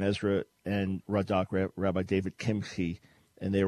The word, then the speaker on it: Kimchi: Years ago, when I was a brand new believer, I was reading something about this Rabbi David Kimchi. That's wrong pronunciation. In case you're just about to get off the air, you're pulling up somewhere, that's the wrong pronunciation. It's Kimchi. But I thought it was Kimchi.